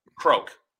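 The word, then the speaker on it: krok: Croak. So imagine yarn, the croak.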